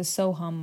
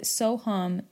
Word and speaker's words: Soham: Soham is spelled S-O-H-A-M. So it's spelled Soham. I was taught that in Sanskrit, H-A-M is pronounced hum instead of ham. You will hear some people pronounce it ham, ham.